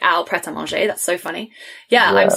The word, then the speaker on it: Prêt-à-Manger: It's called Prêt-à-Manger or something. – It's French. Prêt-à-Manger. It's like ready to eat.